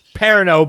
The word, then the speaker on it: Perrineau: You mean Harold Perennial? – Yeah, it's better than... – Did I say that right? – Perino, but it's closer.